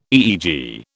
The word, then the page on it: EEG: British English